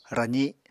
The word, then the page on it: razi: Apatani